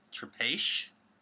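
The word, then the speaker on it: Trapiche: So, let me tell you, it's Trapeche. – Okay. – Is its pronunciation.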